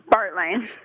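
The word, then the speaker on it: Baertline: Bartline. – Bartline. Okay, it's spelled B-A-E-R-T.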